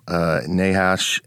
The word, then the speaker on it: Nahash: But it isn't until Nahash and the Ammonites, I think I'm saying that correctly. – That is correct.